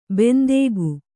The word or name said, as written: ಬಂದುಕ